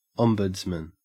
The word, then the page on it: ombudsman: Australian English